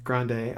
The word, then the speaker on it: Grande: I think the reason that Ariana Grande, I believe that's how you pronounce her name. – Grande, okay. I was like 50-50 on if I was going to pronounce it that way or ignore the E.